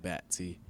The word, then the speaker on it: Batty: But I never really took it to heart like that because, I mean, it's just – I know you can – the way it's spelled, you can pronounce it plenty of different ways, but my family pronounces it Batty.